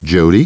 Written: Jodie

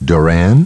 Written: Doran

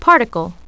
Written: particle

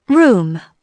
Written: room

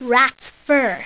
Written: rats fur

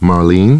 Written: Marlene

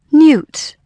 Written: newt

